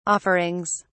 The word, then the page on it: offerings: British English